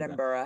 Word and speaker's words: Edinburgh: So anyways, we're not going to do Edinburgh.